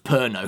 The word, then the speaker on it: Pernod: Well, and also it's pronounced Perno.